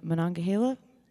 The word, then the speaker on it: Monongahela: I did this sort of road trip that I just kind of wandered around the Monongahela. Is that right? Is that how you say it? Yeah? Okay. I